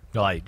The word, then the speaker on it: Licht: Light, Jason Light. It's spelled Licht, but it's Light.